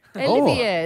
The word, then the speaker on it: Olivier: How is do you say it? Olivier. – I read it as Oliver. – Olivier. – No, Olivier.